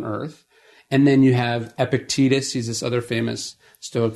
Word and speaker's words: Epictetus: If you say it as, if you think of it as two words, Epictetus, it works. When I try to think of it as one, I don't say it right.